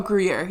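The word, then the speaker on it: Gruyère: It had how do you say it? Gruyere. – Oh, Gruyere. Yeah. – Yeah, Gruyere like cheese.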